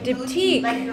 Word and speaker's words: Diptyque: Like diptyque.